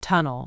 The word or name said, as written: tunnel